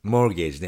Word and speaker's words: mortgage: Mortgage ね。モートゲージと書いて、モーゲージって読むやつね。 – Yeah. There's a silent T, mortgage.、はい、モーゲージね。